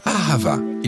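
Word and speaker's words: ahava (אהבה): On le prononce « ahava ».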